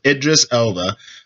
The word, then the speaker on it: Idris Elba: Once again, Idris Elba. That's how he pronounced it.